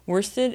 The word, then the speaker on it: worsted: worsted is a type of yarn. And I said it correctly. Worsted is one of two correct pronunciations for that word.